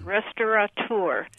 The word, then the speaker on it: restaurateur: That the common folk are picking up and mispronouncing, and it's restaurateur. – Restaurateur. – They're saying restaurateur. Yes.